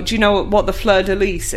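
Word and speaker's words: fleur-de-lis: In French, this is normally pronounced fleur de lis.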